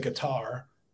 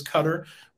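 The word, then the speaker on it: Qatar: Yeah, I think I think most people um, probably say guitar, but uh, uh, the I guess the proper pronunciation is cutter.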